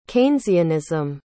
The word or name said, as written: keynesianism